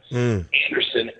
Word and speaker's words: Andersen: But my website is John, J-O-N. Hyphen, not to be confused with the underscore, Anderson, A-N-D-E-R-S-E-N.